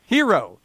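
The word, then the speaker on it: Herro: Both teams are going on a short bench, but you know, whatever this dude, how do you pronounce this guy's name? Hero from Kentucky. Yeah, Hero. He's a hero. – Yeah. Yeah, that kid was amazing. – You're trying too hard. It's just Hero.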